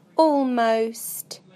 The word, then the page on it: almost: British English